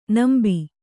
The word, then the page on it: ನಂಬಿ: kannada